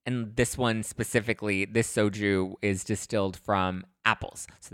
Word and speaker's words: Xoju: I attended a fun launch party for uh, this new soju brand, which is actually called soju, but it's spelled X O J U.